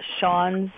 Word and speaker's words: Sian: Sean's Sheehan. – Yeah, it's pronounced as Sean. It's Sean. Irish for Sean. Yeah, Sean's back. – S-I-A-N, right? – Yes, S-I-A-N, yeah.